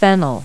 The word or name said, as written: fennel